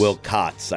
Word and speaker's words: Wilcots: Is his last name spelled W-I-L-C-O-X or C-O-T-S? How do you pronounce it? Do you pronounce it Wilcots or Wilcox? – Wilcots. I think it's C-O-T-T-S.